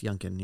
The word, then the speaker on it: Youngkin: That was Glenn Youngkin. Is that how you pronounce it? – Yes, Youngkin. Yeah.